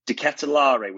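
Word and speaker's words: De Ketelaere: I think De Ketelaar was the last pronunciation I've heard of it, so I'll go with that one.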